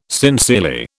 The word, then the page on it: sincerely: British English